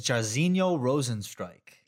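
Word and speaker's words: Jairzinho Rozenstruik: First one I can remember off the bat is Rosinho Jair. I always call him Jair. Is it Jair? – It's Jairzinho Rozenstrike.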